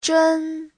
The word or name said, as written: zhen1